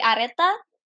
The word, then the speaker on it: Aretha: It's yeah, well, that's that's fine, but it's actually Areta.